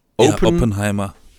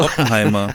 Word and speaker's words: Oppenheimer: Oppenheimer. – Oppenheimer oder so? – Oppenheimer.